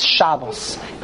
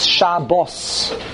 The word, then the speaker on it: Shabbos: So someone explained, I don't know if this is true, but someone explained to me, in Hebrew, there's no such thing as Shabbos. It's Shabbos. It's Milara. The emphasis, the syllable is on the base.